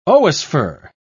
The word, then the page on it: oosphere: British English